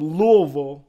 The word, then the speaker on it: Louisville: the good colonel from Louisville, Kentucky. See, you like how I say that now, Coffee? – You got it. It's all just one big mashed-up syllable.